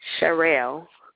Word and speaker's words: Sherelle: Sherelle.